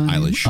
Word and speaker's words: Eilish: Post Malone, Billie Eilish, is that how you pronounce that? – Eilish. – Eilish, thanks.